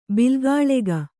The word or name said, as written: ಬಿಲ್ಗಾಳೆಗ